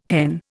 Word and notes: hen: Indian

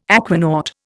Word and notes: aquanaut: Indian

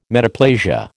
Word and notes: metaplasia: American English

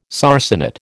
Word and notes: sarcenet: American English

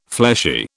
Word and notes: fleshy: British English

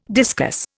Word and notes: discus: Indian

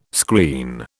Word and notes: screen: British English

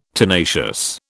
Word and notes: tenacious: British English

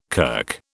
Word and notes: kirk: British English

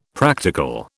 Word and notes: practical: British English

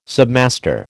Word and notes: submaster: American English

